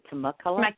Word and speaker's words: Temecula: On the 18th, The Loft, which is in is it Temecula? How do you pronounce that? – Uh-huh.